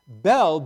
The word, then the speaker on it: Bel: Bel is the chief god, sometimes referred to in our Old Testament as B-A-A-L. Bel, B-E-L, was the Babylonian pronunciation that was a chief god.